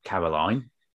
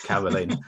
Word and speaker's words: Caroline: And now, obviously, you helped me a lot because your name, I would pronounce it Caroline, okay? But you put the E in brackets to make sure I pronounce it Carolyn, which is great.